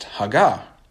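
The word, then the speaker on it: haggah (הָגָה): Now, the English word meditate is a translation of a Hebrew word that's pronounced haggah, haggah.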